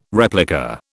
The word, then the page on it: replica: British English